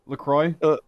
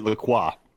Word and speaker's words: LaCroix: Now, so Brandon pronounced it LaCroix. By the way, which is probably the correct French pronunciation. It's some like river in Wisconsin, isn't it named after? That's actually pronounced like, it's like named after a French river, but the Americans came in and bastardized it and call it LaCroix, I think.